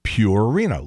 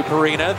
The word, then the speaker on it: Purina: And Claiborne just refuses to pronounce it the way it's supposed to be pronounced, which is Purina, like pure. Things are pure as the driven snow.